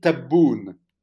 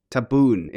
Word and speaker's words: Tebboune: The president, and now again, it's time for the pronunciation unit here, Abdelmajid Tebboune. – Almost. Abdelmajid Tebboune. – Tebboune, important.